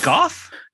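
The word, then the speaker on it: Gough: I think the last name is pronounced Goff or Gaff. – Is it Goff? – Yeah.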